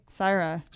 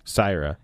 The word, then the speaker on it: Sihra: S-I-H-R-A. – S-I-H-R-A. Syrah. – Syrah.